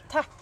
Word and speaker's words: tack: Tack.